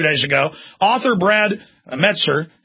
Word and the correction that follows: Meltzer: how do you pronounce his name? – Meltzer.